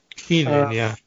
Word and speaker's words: Kenan: Kenan, is I pronounce it right? – Kenan. – Sorrowful.